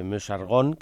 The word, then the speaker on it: Meuse-Argonne: en la batalla de Meuse-Argonne, creo que se dice así, sí,